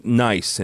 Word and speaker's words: Nyce: Nice, and it's spelled N-Y-C-E, and so I had to ask him beforehand how you pronounce it.